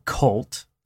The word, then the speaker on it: CVLTE: But we've got the new album by Cult, that's spelled with a V, so C-V-L-T-E.